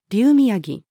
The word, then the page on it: 竜宮城: female